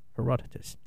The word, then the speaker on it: Herodotus: And I actually pronounced that pretty close to right, because I wrote it down how I would say it. So, it's spelled differently, but how you actually say it is that Herodotus.